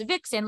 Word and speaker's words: Vicsen: I'm like, so it's Vixen, like V-I-X-E-N, except it's me, V-I-C. S-E-N.